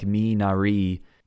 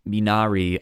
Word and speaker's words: Minari: Should be like Minari. Minari.